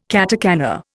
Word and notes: katakana: Indian

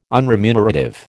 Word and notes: unremunerative: American English

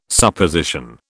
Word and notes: Supposition: British English

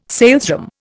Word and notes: salesroom: Indian

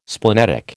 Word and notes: splenetic: American English

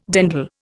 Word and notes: dandle: Indian

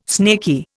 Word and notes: snaky: Indian

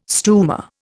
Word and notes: stumer: Indian

